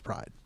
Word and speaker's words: Pryde: That's spelled P-R-Y-D-E. – Not that it matters. – Right. – It's pronounced Pride.